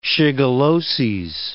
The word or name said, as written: shigelloses